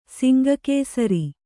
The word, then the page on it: ಸಿಂಗಕೇಸರಿ: Kannada